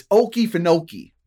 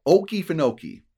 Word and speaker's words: Okefenokee: Now, first of all, you have to pronounce it right. It's Okefenokee. You got to get that southernness to you. Okefenokee.